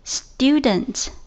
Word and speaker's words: student: 好，最后一个单词我们要学的是和老师对应的学生 ,student, student, student, student. 注意这里虽然是 tu, 但是不能读成 tu, 而是 du, student, 而不是 student。